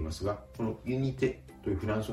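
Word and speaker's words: unité: ユニテですが、あえて言うと語末のて」がポイントでしょうか。フランス語のえ」の発音、これは日本語のカタカナ音 A よりも口を大きく開きます。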